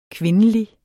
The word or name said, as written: kvindelig